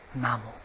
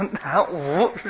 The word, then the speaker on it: 南无: 这个古音呢，在当时翻这两个字，那个音呢就念南无。我们的言语会随着时代去变，字的形状没有变呢，读音呢，古今不一样了，现在都难，都无，都难无，是不是啊？古音念南无，啊，所以这个咒语啊，一定要念当时那个古音，发音才正确，啊，所以这个咒语到现在呀、啊，很多咒子念得不灵了，原因在哪里呢？